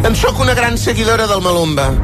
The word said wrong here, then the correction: Maluma: Sí, doncs, no, si és tan seguidora, el que hauria de fer és pronunciar bé el nom de Maluma. Bueno, Maluma, Maluma, ja t'ho he dit.